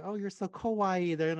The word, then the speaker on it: kawaii: For example, those of you who speak Japanese, of obviously you'll learn kawaii, which means cute, right? And kowai with the O is like basically terrible, ugly, mean.